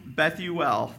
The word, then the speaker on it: Bethuel: Bethuel, that's how she say his name, because it's really important. I'm going to pause on that a little bit to emphasize the L, because what does L mean anytime you see that in a Hebrew Old Testament?